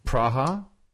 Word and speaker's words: Praha: P-R-A-H-A, I think. – Where's Praha? – I don't know. Is it maybe Prague? Is that how you pronounce Prague if you're a Czechoslovakian? – Praha? – Is it? Is that possible? – Yes, Prague. Yeah, Praha. You pronounce it Prague. Praha.